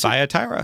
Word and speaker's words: Thyatira: Thyatira. – T-H is Thyatira. – Mm-hmm. – Thigh. I thought you said phi, like phi beta kappa. – Thyatira. Or at least that's the way the Southern boy pronounces it.